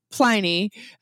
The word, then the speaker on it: Pliny: We're going to call it Pliny the Elder, being that we are Americans, you know, American English, it is a long I with one consonant. So that is why we call it Pliny uh, with the long I. Um, plus, it also rhymes with tiny.